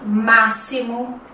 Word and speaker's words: máximo: Outro som que nós encontramos é o som do S. Por exemplo, as palavras máximo, próximo, texto se escrevem com X, mas tem som de S, ok?